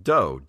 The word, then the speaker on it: Do: Since the theme of the company is retro, the 8-bit is referenced to classic games, and I believe the do in the name is pronounced like doe, D-O-E, as in a martial arts school.